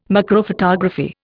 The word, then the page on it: macrophotography: Indian